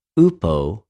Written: οὐπω

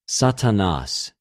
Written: Σατανας

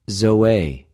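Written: ζωη

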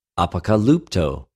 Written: ἀποκαλυπτω

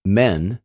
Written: μεν

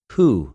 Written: οὑ

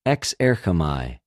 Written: ἐξερχομαι

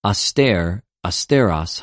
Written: ἀστηρ,ἀστερος

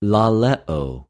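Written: λαλεω

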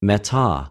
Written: μετα

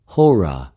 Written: ὡρα

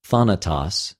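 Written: θάνατος

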